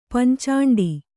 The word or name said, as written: ಪಂಚಾಂಡಿ